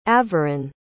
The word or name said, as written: avaron